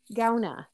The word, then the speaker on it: Gauna: Carrie Gauna, G-A-U-N-A, Gauna, right?